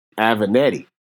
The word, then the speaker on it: Avenatti: Avenatti.